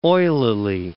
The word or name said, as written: oilily